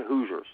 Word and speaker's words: Hoosiers: Am I saying that right? Hoosiers, yeah. – Yeah, Hoosiers.